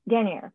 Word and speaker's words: denier: How do you say, is it denier? How do you say it? – Denier, yes.